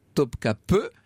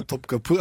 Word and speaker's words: Topkapı: Elle est ravie donc de cette émission sur le harem et elle nous précise bien que ça se prononce que Topkapi se prononce bien Topkapı. Et je vais demander la confirmation à mon autre invité, qui est professeur à Langso et qui, lui, évidemment, maîtrise un peu mieux le turc que moi. Alors, ça se prononce comment, ce palais? – Oui, bonjour. – Effectivement, Topkapı, en fait, qui veut dire la porte du canon.